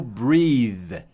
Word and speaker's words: breath: É sem o E no final, é B-R-E-A-T-H, e muda a pronúncia, não é mais I no meio, o E-A não é mais I, agora é E, e fica assim, breath, breath.